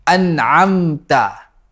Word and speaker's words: أَنْعَمْتَ: Differentiate between Hamzah and عَيْن at the word an'amta. – The first is hamzah, an, and the second one is ayn, am.